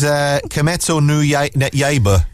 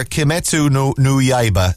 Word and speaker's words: Kimetsu no Yaiba: This is Kimetsu no Yaiba. Someone sent me sent me the correct pronunciation. Kian, I think it was. Demon Slayer Kimetsu no Yaiba, the movie, Mugen Train.